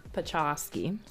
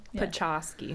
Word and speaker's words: Podczaski: Oh. CZ goes ch, and then ski. Like Pachowski. – Yeah. Why we're saying that is because it's spelled Podczaski.